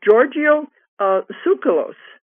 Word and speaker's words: Tsoukalos: But his is Giorgio Tsoukalos. – You pronounce it very well. That's perfect. Absolutely. – Okay, I just said leave the T off, and I guess we're okay.